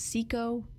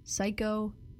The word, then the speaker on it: Cico: Like I do, That was Nobody But Me by The Human Beings and Chico Buff. It could also be pronounced Cico, Psycho, C-I-C-O, Buff by Cocteau Twins.